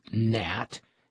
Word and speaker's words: gnat: To provide us with their singular form of a four-letter word, six-point Scrabble word worth a silent G when the plural of gnat has already been used.